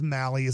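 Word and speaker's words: Mahle: Just so you know, it is Malley is the way you pronounce M-A-H-L-E. I'm horrible with pronunciations, but it is Malley when you're looking at his name.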